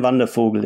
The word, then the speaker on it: Wandervogel: Is it Ich bin kein Wandervogel? Is that how yeah. I pronounce it? Yeah, Ich bin kein Wandervogel, yeah, that's it.